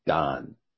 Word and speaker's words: Dan: Dan isn't pronounced Dan. It's pronounced Don.